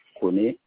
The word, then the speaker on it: Kone: Kone Gaussou. – Alors, vous m'avez dit Kone, ça s'écrit comment? – K-O-N-E.